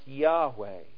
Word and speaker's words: Yahweh: Yahweh, from which we get the English word Jehovah, which sadly has been commandeered by the Jehovah's Witnesses.